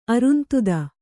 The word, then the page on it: ಅರುಂಧತಿ: arundhati